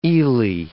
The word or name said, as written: eely